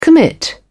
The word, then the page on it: commit: British English